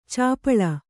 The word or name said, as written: ಛಪ್ಪಳೆ